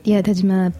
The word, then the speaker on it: Tajima: Tajima beef. – Yes, right. Thank you. You pronounce it much better than I do.